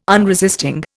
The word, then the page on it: unresisting: Indian